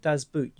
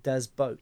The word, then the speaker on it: Boot: Oh, I read recently that you do not call it Das Boot. You actually say Das Boat. – Oh. Well, there you go. – Even though it's spelt boot, the actual proper pronunciation is boat.